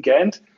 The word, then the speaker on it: GANDT: Ich hatte jetzt gedacht, vielleicht ist das Unternehmen auch, äh, könnte man es auch Gant aussprechen oder, äh, aber es wird schon, ist schon der englische Begriff Gant, aber du hast dann auch gesagt, dass die einzelnen Buchstaben ja eben auch für etwas stehen.